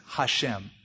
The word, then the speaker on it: Hashem: But do you know how to say the name, Valerie? Shem, that's it. Hashem, the name.